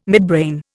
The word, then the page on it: midbrain: Indian